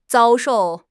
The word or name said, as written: 遭受